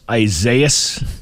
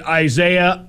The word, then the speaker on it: Isaias: But he's like, I'm calling it Isaiah. I don't know how you people pronounce it, but I'm calling it Isaiah. And I'm like, how do you not know that that's just pronounced Isaias? Why can't you just say that word?